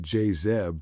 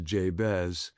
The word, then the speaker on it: Jabez: It's one commentator on this says it's like his mom, instead of pronouncing it Jazeb, pronounce it Jabez.